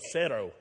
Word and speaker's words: cero: Cero. That's how you say it in Spanish.